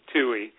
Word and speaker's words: Tui: Tui. – Tui, okay. – Just the way it's spelled. Kind of.